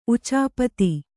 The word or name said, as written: ಉಚಾಪತಿ